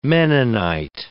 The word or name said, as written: Mennonite